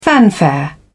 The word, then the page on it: fanfare: British English